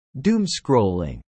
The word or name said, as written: doomscrolling